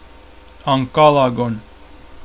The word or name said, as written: Ancalagon